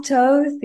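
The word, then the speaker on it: Thoth: Toth? Or is it? – Yeah. Toth.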